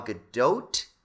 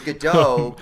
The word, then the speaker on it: Gadot: Yeah, I thought it was Gadot, but I've heard say Gadot. I've also heard Gadot. – I feel like the T is silent. – Yeah, but I think I've heard her actually say it's like you actually say the T.